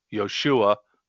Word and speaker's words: Joshua: Jump over to Joshua, which is pronounced really Yoshua, which is similar to Yeshua, which means God saves or salvation.